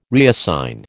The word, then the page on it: reassign: American English